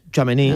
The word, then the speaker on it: Tchouaméni: ¿Cómo se pronuncia? ¿Tchouaméni? – Tchouaméni, ah, sí. Tchouaméni.